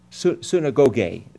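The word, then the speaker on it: Synagoge (συναγωγή): Synagoge would be the better pronunciation if I really spoke Greek, which I don't.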